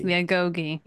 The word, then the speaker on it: Agoge: All right, so the next thing in here we have is, and I always feel like I'm mispronouncing this, the Agoge. – Yes, the Agoge, yes. – The Agoge, okay.